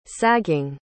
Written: sagging

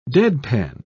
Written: deadpan

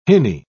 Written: hinny

